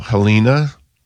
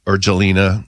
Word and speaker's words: Jelena: And then witch hazel, Helena, or Jelena. Mm-hmm. It's spelled Jelena, but it is. I think you're right in pronouncing it that way.